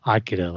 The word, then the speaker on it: Akira: Are we going to say Akira in the English pronunciation, or are we going to say Akira like in the Japanese? So we landed on Akira.